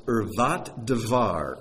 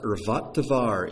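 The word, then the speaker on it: irvat devar (ערות דבר): It's pronounced irvat devar. Irvat devar. It's two words in Hebrew.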